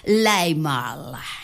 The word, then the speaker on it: Limahl: Bueno, si lo pronunciara mínimamente bien sería Limahl. Ahí está, The Never Ending Story, la historia interminable, el dragón blanco de la suerte, quien tuviera un Fujur en su vida.